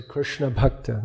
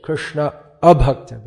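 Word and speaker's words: Krishna-abhakta: You have to be careful how you pronounce that one. If you say Krishna bhakta, then it's the wrong name. Krishna abhakta becomes Krishna bhakta.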